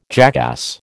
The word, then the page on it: jackass: American English